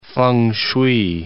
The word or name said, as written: feng shui